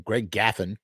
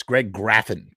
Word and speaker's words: Graffin: They mispronounced his name, Greg Gaffin. I always want to say Gaffin anyway. It's Greg Graffin with an R.